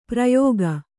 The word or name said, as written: ಪ್ರಯೋಗ